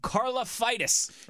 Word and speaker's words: Karlaftis: I always forget how to pronounce his last name. Karlaftis, right? – Yeah, it is.